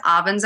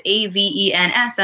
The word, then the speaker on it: Avens: You can find me on Facebook as Avens O'Brien. My name is A V E N S, and I know your podcast displays will show it properly spelled. But hey, look, you pronounced it correctly too, which is awesome.